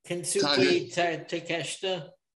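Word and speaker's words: Konosuke Takeshita: Kon- Kanasuki Kansuki Takeshita. – Takeshita, yes. Um, Thank you.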